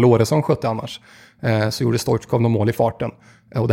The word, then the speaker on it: Stoichkov: Men då försökte ju Bosse Hansson lite kuppa in uttalet Stoichkov. Och la till också, ja, han heter faktiskt så.